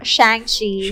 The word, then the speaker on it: Shang-Chi: We're so pretentious, but it's Shang-Chi.